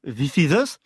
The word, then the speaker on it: This Is Us: This Is Us, je le dis bien là. – Well done, perfect.